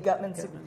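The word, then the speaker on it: Gutmann: Amy Gutman.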